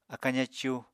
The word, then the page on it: akañ zacú: apatani